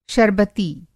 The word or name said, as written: शरबती